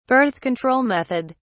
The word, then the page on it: birth-control method: British English